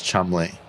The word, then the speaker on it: Cholmondeley: Marchioness of Cholmondeley, which is pronounced Chumley.